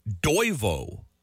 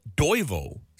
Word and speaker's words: Toivo: And the first one, Toivo, that's how you say it. Toivo, spelt T-O-I-V-O.